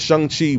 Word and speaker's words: Shang-Chi: I asked him, I said, how do you pronounce his name? He was like, it's Shang-Chi. He's like, he says, I have no clue why people call it Shang-Chi.